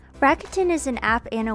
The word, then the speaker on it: Rakuten: Today's episode is also sponsored by Rakuten.